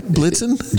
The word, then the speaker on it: Blitzen: Blixen. No. That's like me calling you Flip. – All right, we're going to have to rewind after and check. I said it right. Blitzen? – You said Blixen.